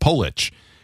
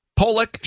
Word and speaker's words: Polich: It's got a long O with an itch at the end. Polich. It's amazing how many people can't get it right. – Ah. – Pollock, short O, Pollock, or Polish.